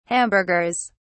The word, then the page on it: hamburgers: British English